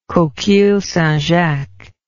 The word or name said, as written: coquilles St. Jacques